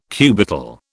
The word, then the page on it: cubital: British English